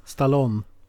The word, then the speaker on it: Stallone: Sylvester, någon som kallas Sylvester Stallone? Är det så man uttalar det? Jag vet inte vem det är. – Stallone. Stallone.